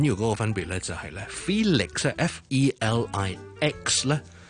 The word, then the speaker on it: Felix: Felix and Phyllis，第二个唔同嘅地方咧就系咧 Felix F E L I X 咧。第一個 syllable 是 E 音嚟嘅，所以咧我係緊少少嘅。